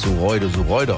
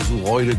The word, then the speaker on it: Suroide: Ich wusste ja vorhin nicht, dass Sureude ausgesprochen wird. Sie schreibt, unser Dorf wird tatsächlich Suroide geschrieben, aber Sureude gesprochen.